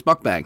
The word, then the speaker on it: mukbang: And then I spent an hour watching mukbang videos. I don't even think that's how you pronounce it. – Yeah, it is. It's mukbang.